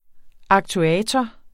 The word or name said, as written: aktuator